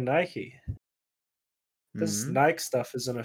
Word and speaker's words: Nike: Is it Nike or Nike? – It's Nike. – Is it? – Yeah, I think so.